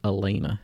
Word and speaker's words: Alana: It's spelled Alana. Remember that? – Yeah. – But it's pronounced Elena.